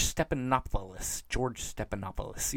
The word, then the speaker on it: Stephanopoulos: George Stephanopoulos.